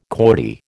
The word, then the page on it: Corti: American English